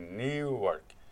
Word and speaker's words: Newark: A pronúncia correta é Newark.